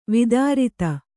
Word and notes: ವೈಧೃತಿ: Kannada

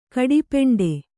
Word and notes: ಗಟ್ಟಿಪಿಂಡ: gaṭṭi piṇḍa

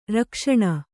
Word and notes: ರಕ್ಷಣೆ: rakṣaṇe

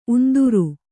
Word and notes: ಉಂದುರ: undura

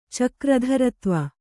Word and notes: ಚಕ್ರಧರತ್ವ: kannada

